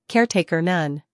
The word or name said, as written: caretaker